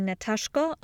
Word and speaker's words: Nataszko: My name is Natasha Polozenko, aka Natashko on all socials, and that is spelled N-A-T-A-S-Z-K-O.